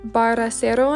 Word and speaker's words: Bracero: Baracero, I think is how you say it, Bracero.